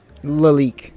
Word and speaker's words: Lalique: 62 Laqui for 20. And a 65 Laqui for, how do you say it? – Lalique. Yeah.